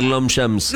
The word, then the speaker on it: Longchamp: Lo pronuncio bien porque es difícil decir Longchamp.